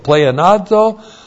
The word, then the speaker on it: pleonazo: Then we have the nominative singular of charis with a definite article, plus the aorist active participle of pleonazo, plus again, the preposition dia plus the genitive plural of pleon with a definite article.